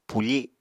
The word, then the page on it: pufi: apatani